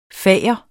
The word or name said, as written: fager